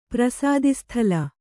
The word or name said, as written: ಪ್ರಸಾದಿಸ್ಥಲ